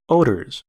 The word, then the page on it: odors: American English